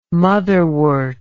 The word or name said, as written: motherwort